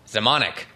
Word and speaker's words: Zemanek: Zemanek.